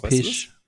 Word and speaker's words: Pysh: Und zwar hat äh, Preston äh, Teig, Zeig, wie spricht man das eigentlich aus? Pisch. Weißt du Psych. Pisch. Pisch. – Preston Pisch. Okay. Also P-Y-S-H.